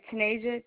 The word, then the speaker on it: Tanaijah: It's Tanasia Berger. Do you need me to spell it? – Yes, ma'am. – All right, Tanasia, T-A-N-A-I-J-A-H, and Berger, B-E-R-G-E-R.